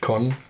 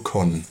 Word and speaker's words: Kon: Mein Name ist Con. Ich heiße nur Con. Nicht Hallo Con, sondern nur Con. K-O-N. Das schreibt man K-O-N.